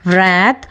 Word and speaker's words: wrath: ಈ ಮೂರು ಪದಗಳಲ್ಲಿ ಡಬ್ಲ್ಯೂ ಇದ್ರೂ ಸಹ ಅದು ಸೈಲೆಂಟ್ ಆಗಿದೆ. ವ್ರ್ಯಾತ್, ವ್ರ್ಯಾಪ್, ವ್ರೆಕ್ ಅಲ್ವಾ.